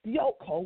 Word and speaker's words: dioko: Dioko, which is spelled D-I-O-K-O, means to pursue, to press toward, to follow after, to be chased or to be pursued.